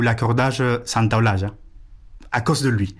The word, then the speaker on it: Santaolalla: Gustavo Santaolalla. – C'est parce qu'il y a deux L à la fin et qu'il faut dire ja.